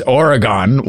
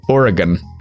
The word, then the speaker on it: Oregon: I fucking love you guys, but if I have to hear you mispronounce Oregon as Oregon one more time, my head will explode. It's pronounced Oregon.